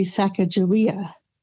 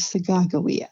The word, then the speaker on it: Sacagawea: Another friend of mine, her great-grandmother, was Sacagawea. I don't know how you pronounce it. I used to say Sacagawea, but she told me, no, it is Sacagawea.